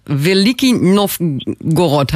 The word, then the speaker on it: Veliki Novgorod: 8 Planwagen, 16 Pferde, 25 Zweibeiner und eine Friedensglocke auf dem Weg nach Russland, nach Veliki Novgorod. Habe ich richtig ausgesprochen? – Gorod. Ja, richtig, korrekt.